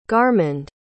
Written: garmond

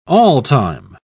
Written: all-time

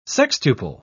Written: sextuple

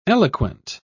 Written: eloquent

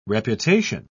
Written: reputation

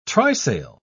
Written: trysail

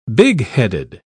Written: bigheaded

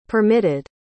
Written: permitted

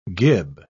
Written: gib

